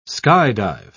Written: skydive

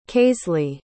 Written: kaisley

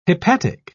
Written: hepatic

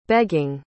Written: begging

